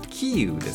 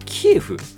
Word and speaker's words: キーウ: はい、最後にキーウです。これもともとキエフと、これウクライナなんです。 – キエフだよね。 – はい、うん、だけど今はウクライナ語に近いキーウという読み方に、変更になったということなんですけれどもね、なんか、まあ、キエフという言葉でもう馴染んじゃったのでなかなかねちょっとあの、はい、慣れないなというところはありますよね、はい、という年でした。